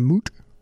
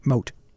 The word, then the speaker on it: mote: But this one, if you have a beam in your eye, are you really going to worry if your brother has a moot or a moat? How would you pronounce it? M-O-T-E, moat.